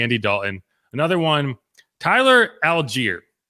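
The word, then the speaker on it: Allgeier: All season, I even had him on my fantasy roster at one point this season, but in my head, I pronounced it Allgaier. And then I watched a Falcons game and they said Algier. And I was like, wait, that's how you say it? And the reason that's funny to me is because there is a place here called Algiers. And I still was saying it the wrong way.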